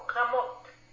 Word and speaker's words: Ramerupt: It's Ramot. You don't pronounce the T and the P.